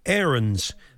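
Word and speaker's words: Aarons: Well, you say that because he was asked today how you pronounce his name. And Max said, Arons. That's how everybody in the family pronounces it.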